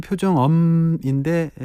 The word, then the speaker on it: 표정업: 발음상으로는 표정엄인데 예, 정확한 글자는 표정업입니다. 없다 할 때 그 업, 표정업.